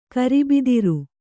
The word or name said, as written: ಗಿರಬ್ದಾರಿ